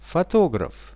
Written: fotograf

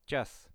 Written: 1-chas

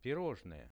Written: pirozhnoe

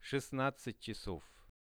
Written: 16-chasov

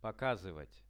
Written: pokazyvat

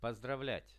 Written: pozdravliat

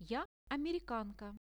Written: ja amerikanka

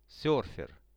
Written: serfer

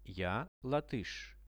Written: ja latysh

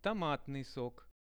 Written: tomatnyi sok

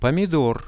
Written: pomidor

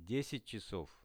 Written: 10-chasov

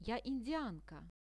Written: ja indianka

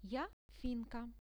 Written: ja finka

